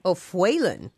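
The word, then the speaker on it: O'Faolain: O'Fuaillan, is that how you say it? – Nuala. – Nuala O'Fuaillan. – No, O'Fuaillan. – O'Fuaillan.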